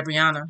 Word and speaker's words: Breonna: Is that how you say your name? – Yeah, Breonna.